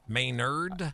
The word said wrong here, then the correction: Mainers: Mainers. How you doing? I'm a Mainer.